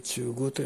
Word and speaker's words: 中宮: 宮に当たると書いて中宮と読みます。